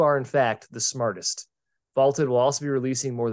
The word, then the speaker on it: VLTED: That's VLTED, pronounced Vaulted.